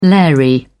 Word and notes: lairy: British English

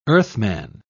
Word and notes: earthman: British English

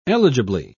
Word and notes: eligibly: British English

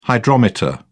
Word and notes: hydrometer: British English

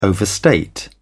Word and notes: overstate: British English

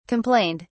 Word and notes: complained: British English

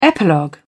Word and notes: epilogue: British English

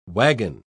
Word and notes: waggon: British English

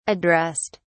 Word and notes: addressed: British English

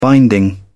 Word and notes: binding: British English

